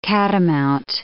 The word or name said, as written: catamount